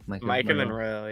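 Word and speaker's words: Maika Monroe: But it's very believable here, and you really before for Micah Monroe, is that how you pronounce her name? – Micah Monroe, yeah.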